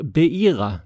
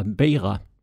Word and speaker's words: Beira: I ska uttalas som ett J, så istället för Beira, som jag sa, så ska det alltså vara Beira.